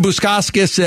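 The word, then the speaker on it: Bukauskas: You can't even say his name right, for goodness sakes. Yeah. – Bukoskis.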